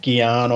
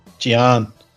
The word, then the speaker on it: Gian: Let's get Gian on. – It's not how you say it, mate. – Gian.